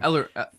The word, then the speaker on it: Ellering: Paul Ehrlich, or I can never pronounce his last name. Ellering, Ellering.